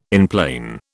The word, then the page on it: enplane: British English